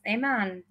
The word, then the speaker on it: Iman: It's Zayna, not Imran. It's Iman, not Ash.